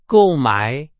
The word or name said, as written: 购买